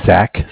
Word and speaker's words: Zach: So my name is Zach, spelled Z-A-C-H. – Z-A-C-H.